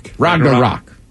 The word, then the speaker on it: Ragnarok: Ragnarok.